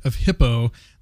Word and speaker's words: Hippo: Of H-I-P-P-O. How would you pronounce that? – I think I'd at least say hypo. – Oh, hypo? Okay. – I don't know. – Hippo? – I'm sure. – Hippo. It's probably hippo.